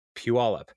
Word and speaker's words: Puyallup: And that's how you say it, people from out of town, Puyallup.